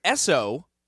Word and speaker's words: Esso: SO is SO, Standard Oil.